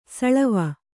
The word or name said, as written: ಸಳವ